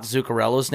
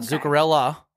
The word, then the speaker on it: Zuccarello: And also tell New York Rangers fans to pronounce Matt Zuccarello's name correctly. – Yep. – It's not Zuccarella. It's Zuccarello. It's with an O.